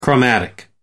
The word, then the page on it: chromatic: American English